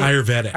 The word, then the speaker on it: Ayurvedic: Ayurvedic.